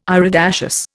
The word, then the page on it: iridaceous: Indian